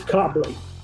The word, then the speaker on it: Cobleigh: Am I pronouncing that right, Ed? It's Cobleigh. Cobleigh.